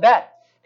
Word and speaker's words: Bet: That's the Hebrew letter B. Actually, it's pronounced Bet. It's like RB. They call it Bet.